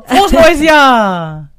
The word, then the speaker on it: Frohes neues Jahr: ¡Frohes neues Jahr!